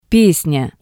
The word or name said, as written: Pesnya